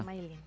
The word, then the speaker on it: Smailyn: S-M-A-I-L-Y-N. – ¿Pero cómo lo pronuncia? – Smiling. – Smiling. ¿Como Smiling? – Sonrisa.